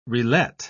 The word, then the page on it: relet: British English